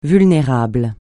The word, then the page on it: vulnérable: francais